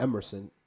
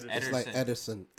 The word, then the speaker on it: Ederson: It's like, it's not Emerson. It's Ederson.